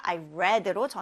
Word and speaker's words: read: 자, 여기서 read는 현재형인데 과거형이니까 I read로 저는 이렇게 발음해 줬어요.